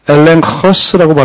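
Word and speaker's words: 엘렌 허스 (ἔλεγχος): 엘렌 허스라고 발음되는데, 엘렌 허스. 이 감마가 뒤에 철자와 결합이 될 때 N으로 발음이 돼.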